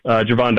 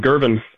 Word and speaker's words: Gervon: Yeah, I'll start with kind of the defensive line as a whole and kind of the addition of Jervon Dexter. And kudos to you for the correct pronunciation, because I foolishly didn't look up how to pronounce his first name and called him Gervon Dexter on the Atlanta Falcons Podcast Network last night.